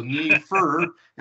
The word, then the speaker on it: Neiffer: N-E-I-F-F-E-R. It was spelled K-N-E-E, and then F-U-R, so Neefe, and that's how it's pronounced.